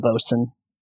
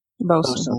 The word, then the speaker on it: boatswain: I think it's boatswain. Unless that's not how you pronounce it. – Yeah, we can call them a bosun. – Bosun. – Okay. Boatswain, same thing.